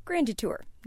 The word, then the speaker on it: Grand Detour: David Nelson is a longtime resident of, yes, Grand Detour, not to be confused with how it's spelled, Grand Detour.